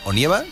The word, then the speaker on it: Onieva: Que yo antes lo he dicho mal, también he dicho Íñigo Onieva. – Onieva o... Sí. Yo, todavía es otoño, no, sí. No es momento de nevar. – Yo Onega, también le llamaba Onega como bueno, periodista.